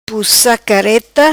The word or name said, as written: Pussa Careta